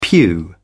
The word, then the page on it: pew: British English